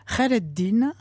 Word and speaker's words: Khayreddin: Comment est-ce qu'on prononce ce nom difficile, ce Hayreddin ? – Khayreddin, voilà. Bon, ça c'est dans tout le Moyen-Orient, on le prononce comme ça.